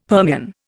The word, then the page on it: Permian: Indian